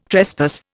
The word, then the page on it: trespass: Indian